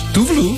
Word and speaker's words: Tove Lo: I think it's actually pronounced Tuvalu.